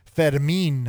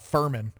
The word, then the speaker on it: Fermin: Jordan Walker, Nelson Velasquez, Alec Burleson, Jose Fermin. Is that how you say it? – Yeah. The pronouncer, the announcers normally say Fermin, but I know it's not Furman.